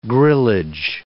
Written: grillage

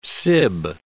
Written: sib

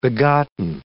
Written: begotten

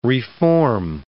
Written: re-form

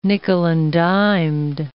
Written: nickel-and-dimed

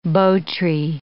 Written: bo tree

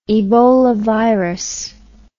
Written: ebolavirus

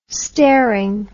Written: staring

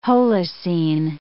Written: Holocene

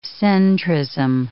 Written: centrism

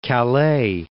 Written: Calais

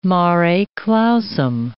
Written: mare clausum

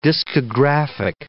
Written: discographic

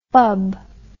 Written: fubb

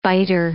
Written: biter

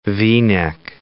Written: V-neck

